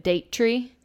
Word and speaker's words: Daughtry: Daughtry. She writes it in the book. Like when she's transcribing it or like saying it into her recorder, she says it Daughtry.